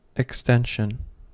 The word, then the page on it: extension: American English